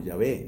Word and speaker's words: Yahvé: Cuando el Eterno dijo, no dice la palabra Dios, ni menos pronuncia el tetragrama, que nosotros pronunciamos como Yahvé.